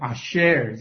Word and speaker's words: asher: 那这个有福在希伯来话里头念成 asher，这个字 asher。 – And in Hebrew, it is pronounced as asher.